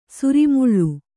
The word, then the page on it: ಸರಿಮಳೆ: Kannada